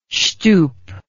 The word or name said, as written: shtup